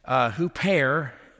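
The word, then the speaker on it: hupair (ὑπέρ): That word "for," hupair, you spell it H-Y-P-E-R in English.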